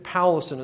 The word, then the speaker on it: Powlison: You spell it P-O-W-L-I-S-O-N. So David Paulson, as you would sound it out.